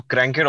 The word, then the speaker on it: Crankit: So, Crankit Automotive. I think I'll start off with the name. It's spelled C-R-A-N-K-I-T, which is crank. So, crank and it. So, I call it Crankit.